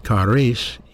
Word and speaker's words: charis: But the Greek word for grace in the New Testament, we spell it G-R-A-C-E, actually is spelled C-H-A-R-I-S, charis. Charis, that's the word grace.